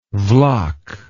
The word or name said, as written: vlach